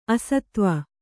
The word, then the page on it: ಅಸತ್ವ: asatva